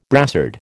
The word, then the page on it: brassard: American English